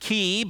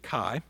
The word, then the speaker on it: chi: Highbrow people pronounce it ki, but I wasn't around when ancient Greek was spoken, and neither were they. We'll stick with chi.